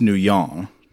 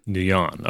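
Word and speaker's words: Nuyen: pronounced her name with a French inflection. So he called her France Nouillon. – Nouillon, okay.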